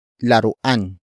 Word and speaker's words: laruan: And that is laruan. Laruan. Laruan. Laruan is spelled as L-A-R-U-A-N.